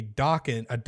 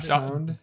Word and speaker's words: dachshund: I saw a woman holding a dachshund. how do you pronounce that word? – Dachshund. – Dachshund. – Dachshund. There we go.